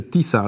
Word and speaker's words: Tisza: Tisza, bo to mówi się Tisa, ale pisze się hashtag Tisza 2026, to jest ten jeden o nazwie partii, a drugi hashtag, który jest warty do wpisywania, to jest Madziar 2026.